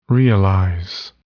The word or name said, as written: realize